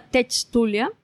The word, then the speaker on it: techtulia: En Twitter estoy como arroba tectulia, eh, la H muda, techtulia.